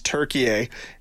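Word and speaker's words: Türkiye: Their next matchup against Turkey. Yes, it's pronounced Turkey now. We got the memo.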